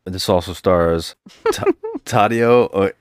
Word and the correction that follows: Tadao: Tadao Takashima.